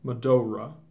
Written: Medora

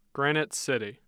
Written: Granite City

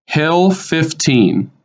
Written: Hill Fifteen

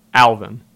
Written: Alvin

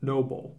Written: Noble

